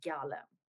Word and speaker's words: Galem: So the correct pronunciation is Galem.